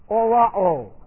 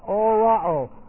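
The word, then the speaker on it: orao (ὁράω): Both of those words are the same Greek word, orao. Now, here's what our word looks like, orao. Rough reading, H-O-R-A, long O, orao.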